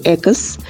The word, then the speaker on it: ЭКОС: С вами корреспондентка пресс-избы и директор мастерской ЭКОС. Надеюсь, правильно ставлю ударение. Привет. Эй, привет. Да, ты, конечно, ставишь правильное ударение. Мы действительно экос.